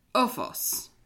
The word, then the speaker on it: Oofos: O-O-F-O-S. – Ufos. – That's right. I even did the little translation thing so I could try to learn how to say it pronunciation wise. But Ufos.